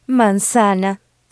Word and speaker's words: manzana: M-A-N-Z-A-N-A. – Okay. – So the letter Z doesn't buzz like it can in English. It's not the Z of zebra, but rather the S of sand. – Manzana.